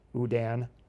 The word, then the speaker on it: Houdin: I've got the phonetic pronunciation after that. Udan. – The Udan box. The Udan box. No, not Udan. – So why is it funnier when you say it correctly? – It just is.